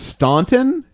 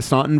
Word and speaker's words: Staunton: it's Staunton. Am I saying it right? Mm-hmm. Staunton, Virginia, May 26th.